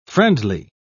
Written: friendly

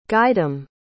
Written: guydom

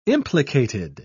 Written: implicated

